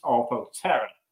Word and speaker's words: Orthoptera: And I was like, it's Orthoptera, because you've got it right. Ortho, Greek for straight. – Uh-huh. – Terra, that's for wings.